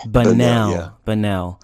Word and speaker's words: Bunnell: And is it Bunnell, Florida? Am I saying that right? – Bunnell. Yep.